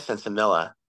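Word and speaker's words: sinsemilla: sensimilia that brings us all here? Is that the acceptable pronunciation? I don't know if it's a LL, you know, sensimilia, sensimilla. – Yeah. I've heard it pronounced both ways.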